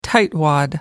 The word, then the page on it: tightwad: American English